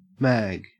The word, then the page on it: mag: Australian English